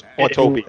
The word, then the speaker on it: Autopia: This one jumped ahead for me when I went on an Autotopia. Is it Autotopia? – Autopia. – Disneyland. Autopia, I'm putting in another syllable, but okay.